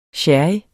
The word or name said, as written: sherry